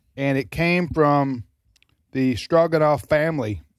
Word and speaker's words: Stroganoff: It's spelled, the Russian spelling is Stroganov. – Stroganoff. – But the English, we do it Stroganoff, which is phonic, stroganoff. But it's Russian.